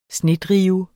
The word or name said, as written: snedrive